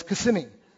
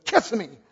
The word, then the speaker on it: Kissimmee: That's not the way you pronounce it, by the way. It's Kissimmee. But everybody from the North Coast says, Kissimmee.